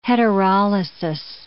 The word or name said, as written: heterolysis